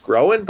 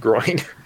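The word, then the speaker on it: Gróin: The consensus is pronounced Glowen, but I didn't realize his father's name was pronounced Groen, but spelled Groin. So wow. – So by my pronunciation, he would have been Gloin, son of Groin.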